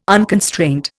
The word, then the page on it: unconstraint: Indian